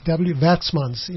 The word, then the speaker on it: Werksmans: Worksmans is spelled WERKSMANS. – Yes. – Works, Watsmans in Afrikaans. – Yes, yeah. That would be the Afrikaans version of it, yes.